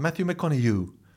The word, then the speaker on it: Matthew McConaughey: Matthew McConaughey.